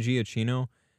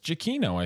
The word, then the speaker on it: Giacchino: So Michael, so I realized I was saying his name wrong, for years. – Giacchino. – I was saying Giacchino. Apparently that's not it. – It's Giacchino, I think, isn't it? – Yeah. You're right, man.